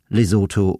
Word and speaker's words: Lesotho: Außerdem muss man die Ausnahmen kennen. Als denglische Patienten brauchen wir uns nämlich gar nicht mit der Aussprache von TH abzumühen, wenn es ganz einfach wie ein T gesprochen wird, in Namen wie Theresa, Thomas, Thompson, Thames, Thai, Lesotho oder Thyme, dem Gewürz Thymian.